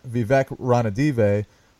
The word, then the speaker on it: Vivek Ranadive: What happened that Vivek Ranadive, whose name I can now pronounce? – Awesome.